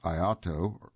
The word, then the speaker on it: IAATO: Much hashing out has ensued over the decades, and the body that oversees tourism, the International Association of Antarctic Tour Operators, and that's IAATO, and is pronounced IATO,